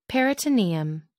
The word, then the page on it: peritoneum: American English